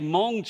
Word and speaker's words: Hmong: This is a Hmong church. It's spelled H-M-O-N-G in Hanoi, Vietnam.